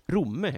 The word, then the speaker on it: Romme: Folk i Romme blir arga på att vi stockholmare kommer dit och tar deras pister, deras pyttepanna och uttalar deras ort fel. Romme heter det.